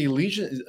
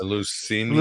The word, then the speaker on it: Eleusinian: In fact, a lot of the Elysian, uh, um, Elysian school mystery schools, I think they were called the Elysian mystery schools. – Eleusinian.